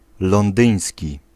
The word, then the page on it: londyński: [ˈlɔ̃n̪d̪ɨ̃ĩ̯s̪ʲci]